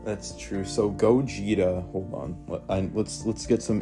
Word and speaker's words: Gogeta: His name, it's pronounced Gogeta. – Gogeta.